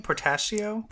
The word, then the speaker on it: Portacio: before the new X-Men run starts and after Extinction Agenda, there's like a really fun run of Wills Portacio. How do you say his name? Portacio? – Portacio, yep.